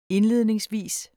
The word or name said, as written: indledningsvis